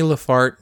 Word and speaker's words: Laferte: Now, in 1926, a woman named Annie Laferte. – How do you spell it? – L-A-F-E-R-T-E. Laferte. – L-A-F-E-R-T-E.